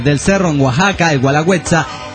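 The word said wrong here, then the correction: Guelaguetza: sino Gualagüetza, como se dijo en el programa pasado.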